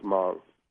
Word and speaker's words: Hmong: It's spelled Hmong, but pronounced Hmong.